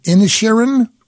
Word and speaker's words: Inisherin: Best Picture nominations: All Quiet on the Western Front, Avatar, The Way of Water, The Banshees of Inisherin. Did I say that correctly? – Correct. Yes, you did.